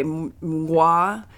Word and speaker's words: Mngwa: In Tanzania, it's spelled M-N-G-W-A. Mngwa is a ferocious nocturnal cat. – Mngwa? – Perhaps. There you go. That might be it. – That's how I'm going to try to pronounce it.